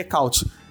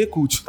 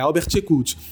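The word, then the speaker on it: Eckhout: É, e também foi bom você ter falado como é que se escreve o nome dele, porque a pronúncia não é Ecaut. É, a gente, com essa mania britânica, é, estadunidense, leria o nome dele e falaria Albert Ecaut, porque tem o, o, o T, out de saída, mas na verdade a pronúncia no holandês é Ekut, tá? É Albert Ekut.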